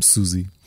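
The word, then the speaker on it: Siouxsie: E depois fui ver a explicação que não, não se diz, não se pronuncia como eu, como eu dizia. É mesmo Suzy.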